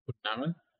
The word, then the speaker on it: Khulna: K-H-U-L-N-A. – Kulna? – K-H-U-L-N-A.